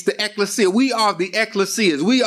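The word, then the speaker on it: ekklesia: It's pronounced ecclesia.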